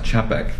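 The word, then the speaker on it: Čapek: You know, the guy who, Carol, I think it's pronounced Chapek,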